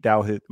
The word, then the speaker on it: Douthit: And what we are going to be doing here now, first and foremost, I'm your co-host, Filippo, and Pete Douthit. We are with me. Pete, did I pronounce it right correctly? – Yeah, pretty much. It's Pete Douthit. There's a T Douthit in there, but yeah.